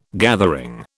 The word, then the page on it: gathering: British English